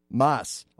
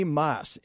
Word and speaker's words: Maas: Maas. Okay. – Yeah. – Tony Maas, M-A-A-S.